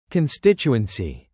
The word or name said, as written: constituency